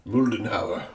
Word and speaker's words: Moldenhauer: How would the Germans pronounce your last name? – Moldenhauer.